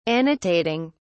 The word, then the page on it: annotating: British English